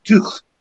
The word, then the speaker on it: Tough: and that's why in Aberdeenshire, for instance, the surname is often pronounced Tuch.